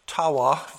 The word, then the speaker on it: Tawa: In Nicholas Tawa, that's how you say his name, from Psalm to Symphony, History of Music in New England.